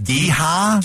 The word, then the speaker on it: GEHA: You know it's called Geha? Arrowhead? They renamed it Geha? – Geha. – Spell that. – G-E-H-A, all capitals.